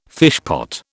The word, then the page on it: fishpot: British English